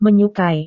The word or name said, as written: menyukai